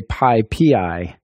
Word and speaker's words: PyPI: The official way to say it is PyPI. It's Python Package Index. So, you know, you put the emphasis on the PyPI.